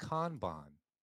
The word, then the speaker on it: Kanban: I heard it pronounced Kanban on the Toyota way, and it was actually instituted at Toyota.